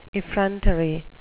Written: effrontery

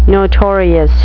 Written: notorious

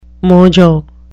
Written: mojo

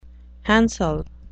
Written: handsel

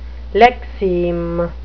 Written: lexeme

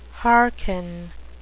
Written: hearken

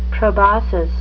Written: proboscis

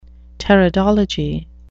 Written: pteridology

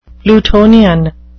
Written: plutonian

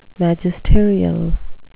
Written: magisterial

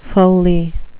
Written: foley

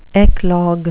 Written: eclogue

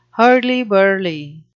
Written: hurly-burly

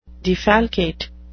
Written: defalcate

